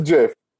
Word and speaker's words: GIF: So I think we're probably right, but I'm going to go with it's a GIF. – Wow. – It's a GIF. It's like giraffe.